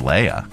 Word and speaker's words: Leia: 99.9% of the time on Alderaan, it's Leia. You're welcome, nerds.